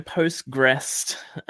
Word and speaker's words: PostgREST: Yeah, so Postgrest. Uh, so this is like Postgres, but with a T on the end.